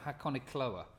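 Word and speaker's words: Hakonechloa: Hakone. – Then the E. Pronounce the E. – Hakone. – And then C H L O A. – Oh, Hakonikoloa. – I think that's Chloa.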